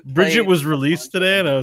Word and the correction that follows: Brigitte: Isn't it Brigitte? – It's Brigitte, yeah.